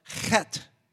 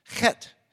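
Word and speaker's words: Chet (ח): The number eight is a letter pronounced Chet, Chet.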